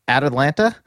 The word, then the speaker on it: Atalanta: I can never pronounce it right. It's At Atlanta. – Perfect. Spot on. Yep.